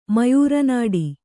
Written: ಮಯೂರನಾಡಿ

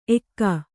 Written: ಎಕ್ಕಾ

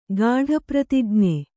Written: ಗಾಢಪ್ರತಿಜ್ಞೆ

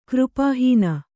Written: ಕೃಪಾಹೀನ

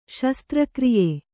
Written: ಶಸ್ತ್ರಕ್ರಿಯೆ

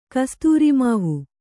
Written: ಕಸ್ತೂರಿಮಾವು

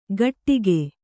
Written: ಘಟ್ಟಿಕೆ

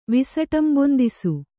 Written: ವಿಸಟಂಬೊಂದಿಸು

